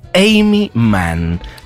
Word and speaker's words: Aimee Mann: Amy Mann. Chicos, toman nota, ¿eh? Se escribe Aime con dos E y Mann con dos N.